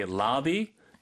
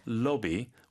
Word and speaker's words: lobby: Just safely say lobby with the American accent 네. or lobby with the British accent.